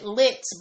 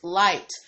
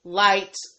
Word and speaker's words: LYT: I pronounced it Lit, but it's actually pronounced Light. Okay, thank you, TS. So you remember this? You did remind me. I'm bringing it back up today again because I was pronouncing the name of the service wrong. I was just pronouncing it Lit like Lyft, but it was pronounced Light, as in, you know, a light.